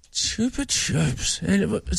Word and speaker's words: Chupa Chups: Chubba Chubs. – Yeah, Chupa Chup Pop without this. – Chupa Chups. Spanish, Megan. Megan.